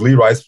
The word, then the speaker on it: Lee Rice: I just had my name, and I had it spelled with a capital L, small e, E, capital R, you know, then R-I-C-E, lowercase. So it was Lee Rice for Senate.